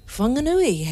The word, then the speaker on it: Whanganui: Whanganui has a soft F.